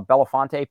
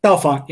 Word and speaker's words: Bellefonte: So uh, coming in to us from uh, Belafonte, PA. Is that where you're you're based? Bellefonte.